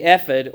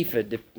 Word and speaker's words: ephod: And then he transitions to talking about the individual garments, beginning with the ephod or the ephod. You can pronounce it either way. You take your pick. If you look it up in the dictionary, it's pronounced both ways.